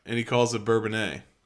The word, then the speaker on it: Bourbonnais: We stayed in a hotel in Bourbonnais. – Bourbonnais. – Is that what it's called? – Yeah. – Bourbonnais? – Yeah, it's French.